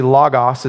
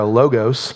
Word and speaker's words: logos: The Greek word there is a Greek word, technically logos is how you say it. We say in America, logos.